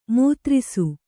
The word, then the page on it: ಮೂತ್ರಿಸು: mūtisu